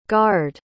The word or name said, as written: gard